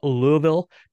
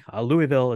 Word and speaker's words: Louisville: We had some friends getting married just outside of Louisville, Kentucky. I hope I'm saying that right. Apparently, I was saying it wrong. Louisville is incorrect. Louisville is more correct, I guess.